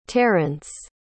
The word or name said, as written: terrance